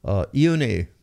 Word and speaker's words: Ione: Ione is how Bingham pronounced June in Hawaiian.